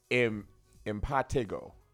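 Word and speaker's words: impetigo: By the way, we're pronouncing it wrong. It's pronounced impatigo. Impatigo.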